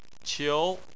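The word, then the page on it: qiu: tone 2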